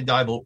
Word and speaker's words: Dybul: Tanya Dybul rhymes with Bible because it's not spelled that way at all.